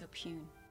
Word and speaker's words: oppugn: Opung? – I believe it's opune. – Opune. A pun. O-P-P-U-G-N.